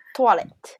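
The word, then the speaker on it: toilette: トワレット。 – あ、なるほど。全然違うね。 – そう。英語と違って発音、 OI の発音が、オア。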